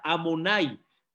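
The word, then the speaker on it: Adonai: el que no se pronuncia como está escrito, se pronuncia de otra manera, se pronuncia Amonai, el patrón.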